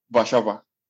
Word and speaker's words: Warszawa: English people pronounce that as Warsaw. That is the capital, but Polish people pronounce that Warszawa. – Oh, Warszawa.